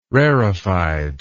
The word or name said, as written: rarefied